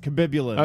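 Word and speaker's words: Khabibulin: K-H-A-B... It's, yeah. Kabib... B-I-B-U-L-A-N. – Kabibulin. – That's how you spell it. It's Kabibulin.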